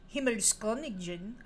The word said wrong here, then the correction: Himmelskönigin: I know I said that wrong, in German. I practiced some of the other pronunciations, but I didn't know this one. Himmelsko, with an umlaut, N-I-G-I-N. Himmelskönigin.